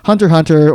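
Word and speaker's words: Hunter × Hunter: of where the X is silent, kind of like there's a show it's spelled Hunter X Hunter or like Spy X Family, but you don't pronounce the X. It's just Hunter Hunter or Spy Family.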